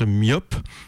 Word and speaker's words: MYOP: C'est la deuxième fois aussi que c'est co-organisé avec l'agence MIOP. Donc MIOP, ça s'écrit M-Y-O-P.